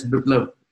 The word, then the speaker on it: Biplab: Yeah, it's Biplab.